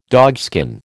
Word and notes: dogskin: American English

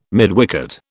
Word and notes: midwicket: British English

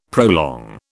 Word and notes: prolong: British English

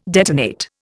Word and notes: detonate: Indian

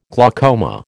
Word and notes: glaucoma: American English

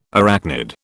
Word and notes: arachnid: British English

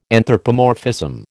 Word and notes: anthropomorphism: American English

